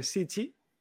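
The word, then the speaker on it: sichi (しち): En el 7, que hemos dicho que no se dice nana, sino que se dice sichi.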